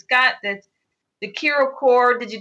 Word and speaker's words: Ki-Ro: And at first I was like, is that Kika's and Roberto's name tied together for Kiro? Is that how you pronounce it? – So, yes, Kiro.